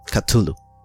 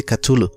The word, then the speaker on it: Cthulhu: Cthulhu.